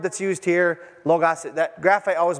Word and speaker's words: logos: In the Greek, it's the phrase is called logos, or logos really is the way it's pronounced, but logos, whatever.